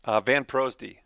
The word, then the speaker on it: VanProosdy: VanProosdy.